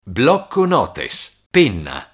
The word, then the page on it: blocconotes: Italian